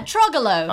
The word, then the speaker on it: Troglot: Spelt with a T on the end. It's a French root word. T-R-O-G-L-O-T.